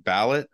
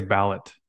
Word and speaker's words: Ballet: Is it ballot or ballet? – I think it's ballot, like voting. – Ballot. Okay. Yeah, B-A-L-L-E-T.